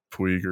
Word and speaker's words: Puig: His name's Pooch. Not Ricky, but his last name is Puig or Puig or Pooch. – However. Pooch? Is that how it's pronounced in Spanish? – I think that's how you say it.